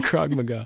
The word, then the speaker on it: Krav Maga: Write in, correct me if I'm wrong about the Israeli military training in Krav Maga.